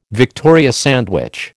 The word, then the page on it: Victoria sandwich: American English